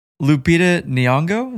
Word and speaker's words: Lupita Nyong'o: Lupita Nyong'o? Is that how you say it? – Mm-hmm.